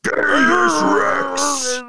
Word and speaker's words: Genghis Rex: Say it correctly. It's Genghis Rex. – Genghis Rex.